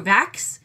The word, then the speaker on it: Vachss: My next pick is Hard Candy by Andrew Vax. Rhymes with tax, but it's actually spelled V-A-C-H-S-S.